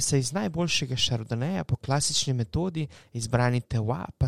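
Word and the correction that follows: terroir: Kako lepo si to povedal? Tewa. Je to pravilna izgovorjava, Klaudija? – Teroa. – Teroa. Teroa. Potlem pa, je pa Google se zlagal. Google je rekel tewa.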